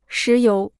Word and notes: 石油: shí yóu

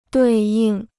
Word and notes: 对应: duì yìng

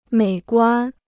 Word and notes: 美观: měi guān

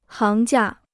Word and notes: 行家: háng jiā

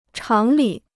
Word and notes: 常理: cháng lǐ

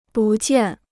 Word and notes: 不见: bù jiàn

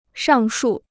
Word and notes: 上述: shàng shù

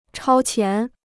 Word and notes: 超前: chāo qián

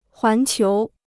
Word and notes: 环球: huán qiú

